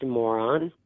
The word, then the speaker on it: Moran: And our name is spelled M-O-R-A-N, but it's pronounced moron.